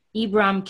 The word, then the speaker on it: Ibram: And founded by activist Ibram Kendi. Is that how you pronounce his name? Ibram? Mm-hmm. And it's so interesting how people talk about anti-racism and some people say, well, I'm not racist.